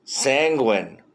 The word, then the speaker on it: sanguine: Sanguine. Oh, so it does rhyme with penguin.